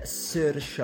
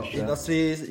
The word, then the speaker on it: Saoirse: Come cazzo si dice Saoirse Ronan? – Si dice Saoirse. Adesso Saoirse. I nostri ascoltatori sanno una cosa nuova, cioè che Saoirse Ronan si pronuncia Saoirse.